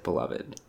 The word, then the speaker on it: beloved: So, I mean, I feel like I came under scrutiny there, both because I called Team America beloved and also because I pronounced it beloved, which is, I think, technically also correct, but a strange way to do it. And I don't defend it. – No, I think that's fine to say the word beloved that way. You know, whatever. – Yeah, it's okay. Syllables. It's your choice.